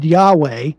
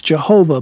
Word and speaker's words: Yahweh: Yahweh, which used to be called in some of the older versions Jehovah, but the proper translation or proper pronunciation or much closer pronunciation from the Hebrew is Yahweh,